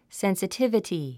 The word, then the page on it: sensitivity: sènsətívəti; センスィティバティ